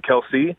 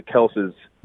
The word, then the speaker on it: Kelce: Shout out to all the Kelces that are in the family tree. I hope we're not pissing you off. But, so it's really it's a silent E on your family tree.